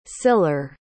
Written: siller

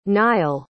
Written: Nyle

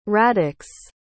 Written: raddix

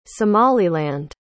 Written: somaliland

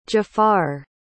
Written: jafar

